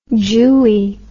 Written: jewy

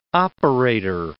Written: operator

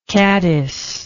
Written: caddiced

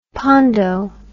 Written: pondo

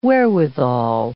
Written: wherewithal

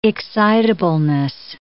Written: excitableness

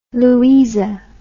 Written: louisa

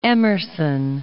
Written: Emerson

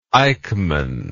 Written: Eichmann

